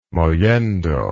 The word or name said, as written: mollendo